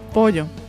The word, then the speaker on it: pollo: Pollo. Pollo. Double L is the Y, right? Pollo. They say it pollo, So when they say pollo, you know, you're like, ah, that guy's Argentinian.